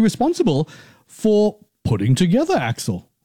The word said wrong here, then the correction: AIX: Legal. – Yeah, this program, AIX Legal. – Oh, AIX Legal.